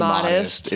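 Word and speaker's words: Modist: I think it's pronounced modest. It's Modest.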